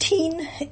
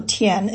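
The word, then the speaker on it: tien (天): So this concept, it's pronounced tien in Cantonese or tian in Mandarin.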